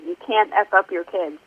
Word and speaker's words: You Can't F Up Your Kids: So how do you say the name of your book, actually, Lindsay? – Exactly as you did. You Can't F Up Your Kids.